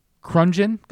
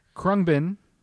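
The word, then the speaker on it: Khruangbin: Oh, I said it wrong. Krungbin? It's a difficult... – It's an exercise in pronunciation. – It's an exercise in pronunciation, so much so that this band has taken the time to tell people at their concerts how to pronounce their name. The group is called Krungbin. – You did it.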